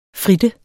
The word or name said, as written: fritte